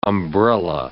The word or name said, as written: umbrella